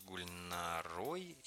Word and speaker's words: Гульнарой: А мы пока побеседуем с Гульнарой из Казани. Правильно я ударение поставил? – Да, правильно.